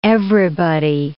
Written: everybody

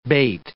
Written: bait

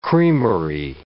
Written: creamery

